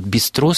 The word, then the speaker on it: бистро: то мы взяли вот это вот «бистро» с французским ударением на последний слог, забыв о том, что вообще-то это исконно русское слово.